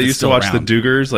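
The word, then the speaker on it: Duggar: No, listen, you're actually doing it correctly. I'm doing the southern spin on it with the Duggars. That's how we do it down here. You're doing the more actual, real pronunciation of it.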